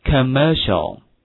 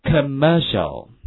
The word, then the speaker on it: commercial: 广告这个词注意一下发音的话非常的独特，就是有三个恶的音，跟我读一下 commercial commercial, 哎，广告，那外国广告做得好啊，对不对？